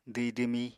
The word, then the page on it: dwhi-dwmí: apatani